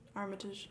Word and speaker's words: Armitage: Armitage.